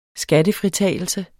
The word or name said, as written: skattefritagelse